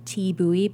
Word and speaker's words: Thi Bui: So on Instagram, I'm Thi Bui, but it's spelled TEABUOY, like the pronunciation of my name.